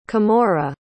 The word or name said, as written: camorra